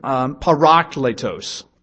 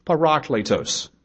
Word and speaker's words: parakletos: is the word parakletos. Parakletos. It's spelled P-A-R-A-K-L-E-T-O-S.